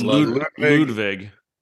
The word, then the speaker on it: Ludvig: So actually, is it Ludvig? – It's Ludvig.